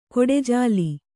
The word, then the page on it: ಕೊಡೆಜಾಲಿ: koḍe jāli; kannada